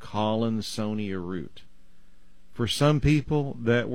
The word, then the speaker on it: Collinsonia: It's C-O-L-L-I-N, mm-hmm. And then S-O-N-I-A, almost like the word Sonia. Collinsonia.